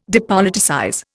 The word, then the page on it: depoliticize: Indian